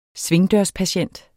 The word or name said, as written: svingdørspatient